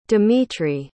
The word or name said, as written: dimitri